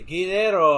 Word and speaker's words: Guerrero: Does she roll the R's? – Oh yeah, good. She would say Guerrero.